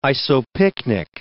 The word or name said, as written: isopycnic